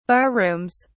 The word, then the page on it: barrooms: British English